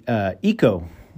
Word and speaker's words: Ico: I was playing, it's pronounced actually Ico. A lot of people say it's Ico or ICO or whatever.